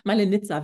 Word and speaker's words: Malenica: Malenica, wie Malle und Nizza.